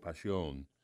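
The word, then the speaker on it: Pasión: I just poured some of the Chardonnay, and this is part of the Passion, P-A-S-I-O-N, with the emphasis on the O, Passion.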